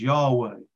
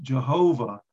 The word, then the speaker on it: Yahweh: Now that name is what we would pronounce as Yahweh. It's also pronounced Jehovah.